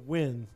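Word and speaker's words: Nguyen: So Nugent is the most common surname in which country? – Nugent? – Yes. Vietnam, Korea, Japan, or Singapore? – Oh, win. Pronounced win. – Oh, pronounced win? – N-G-U-Y-E.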